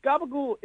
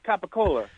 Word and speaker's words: capicola: See, Gabagool is just capicola. – Yeah, I know. – That's how they say it in Jersey.